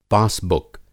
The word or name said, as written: पासबुक